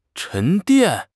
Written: 沉淀

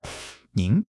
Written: 您

